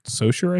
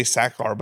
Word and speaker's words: Sachar: Let's see. Oh, you're right. Sachar.